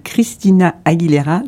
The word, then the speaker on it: Christina Aguilera: On va se quitter avec Candyman de Christina Aguilera.